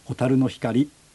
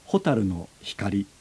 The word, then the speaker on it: 蛍の光: メロディーの高低に合わせて蛍の光というふうに、まあ、タイトルもそういうふうに言ってしまいますけれども、本当は自然のアクセントで言えば蛍の光というアクセントになるはずですね。